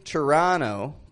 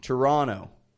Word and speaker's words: Toronto: And so we don't say Toronto, but Toronto. We're going to Toronto. An American, however, Toronto. Toronto. Tiny differences. Listen for them yourself.